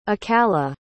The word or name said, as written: akala